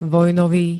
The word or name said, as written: vojnový